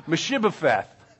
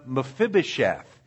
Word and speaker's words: Mephibosheth: And then when you get to a name like Mephibosheth, it's mispronounced. Meshibopheth. it's M- Mephibosheth.